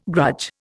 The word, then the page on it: grudge: Indian